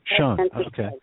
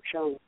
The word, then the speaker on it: Schoen: Schoen, okay. – Schoen.